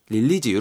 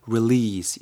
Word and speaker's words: release: 릴리즈 이렇게 발음하지 마시고 release 이렇게 발음하시면 될 것 같습니다.